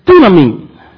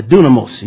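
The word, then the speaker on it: dunamin (δύναμιν): Again, here at the Grace Gospel Church, we utilize the modern Greek pronunciation. Amen. So the word here is dunamin. Now, if I was in Erasmus, I used the root word, I would say dunamos.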